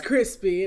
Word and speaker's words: Chrispy: and my name is Crispy,